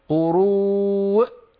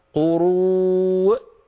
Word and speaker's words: قروء: لا تقول يا هدى، لا تقولي قروء، لا تنطقي الهمزة مرتين، قروء فقط، أقفل الوترين الصوتيين. – ثلاثة قروء. – لا أنت تنطقين الهمزة مرتين، فقط أقفل الوترين الصوتيين مرة واحدة تخرج الهمزة، هكذا قروء وفقط.